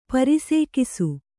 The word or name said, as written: ಪರಿಸೇಕಿಸು